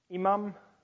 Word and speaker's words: Imam: Imam, mummy. I'm saying it wrong. Imam.